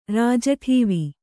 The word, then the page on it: ರಾಜಠೀವಿ: kannada